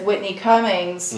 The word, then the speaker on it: Whitney Cummings: Well, it's kind of like that episode of Keep It with Whitney Cummings, mm-hmm.